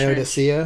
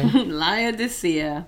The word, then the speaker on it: Laodicea: Laodicea, church? – That's what I would say. – Laodicea.